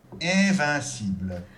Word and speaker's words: invincible: Since we have I-N in the first and second syllables of this word, we get the closed E nasal, followed by an open E nasal. – Invincible.